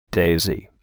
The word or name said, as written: daisy